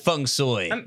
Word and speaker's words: Feng Shui: Yeah, it's like Feng Shui or Feng Shui. I'm just going with Feng Shui because I think that's like the authentic Cantonese pronunciation.